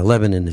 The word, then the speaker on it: Lebanon: Now, Lebanon is spelled Lebanon, but it's pronounced locally Lebanon.